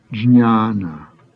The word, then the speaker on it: jñāna: Students find difficulty in pronouncing the ñ sound, which immediately follows after a J. It should be pronounced as the N in onion. For example, jñāna, knowledge.